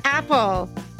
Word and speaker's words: Appel: It's spelled A-P-P-E-L, but we just say it Apple.